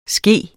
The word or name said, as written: ske